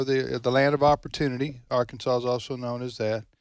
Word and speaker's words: Arkansas: She properly pronounces her home state for those traveling through. Go ahead. Okay. – This is Glenda, McCrory, Arkansas.